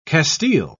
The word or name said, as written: castile